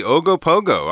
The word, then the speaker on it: Ogopogo: Legend says that there is a monster living in a lake in western Canada, and its name is Ogopogo. – Did hmm. – I say that right? That's right, exactly. Ogopogo.